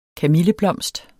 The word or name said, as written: KAMILLEBLOMSTER